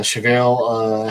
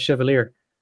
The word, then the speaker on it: Chevalier: He's American, so he might actually have pronounced that Chevalier. – Chevalier. – I'm just reading it as Chevalier because that's a French word.